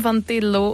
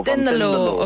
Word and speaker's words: Tendelo: Ik ga ook eens naar Lier, want dat is eindelijk nog eens wat tegenwicht bij Kim van Telo, of is te de lo? Wat is het? – Ten Kim? – Lo. – Wat? – Hallo, goedenavond. – Hallo. Hoe spreek je je achternaam uit? – Tendelo. – Tendelo, oké.